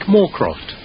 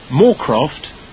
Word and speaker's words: Moorcroft: Yes, Frank Moorcroft. – Oh, could you spell that, please? – Yes, Frank, F-R-A-N-K, Moorcroft, M-O-O-R-C-R-O-F-T.